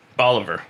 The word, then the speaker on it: Bolívar: Rhymes with Oliver. Bolívar.